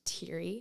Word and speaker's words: Thiery: Do you know my last name is actually pronounced Thiery? – Oh, my gosh.